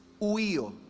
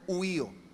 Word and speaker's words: huío: Y luego, la última palabra que la Biblia utiliza para definir hijos es huío. Dígalo conmigo, huío. U-I-O, así, U-I-O, así se escribe, huío.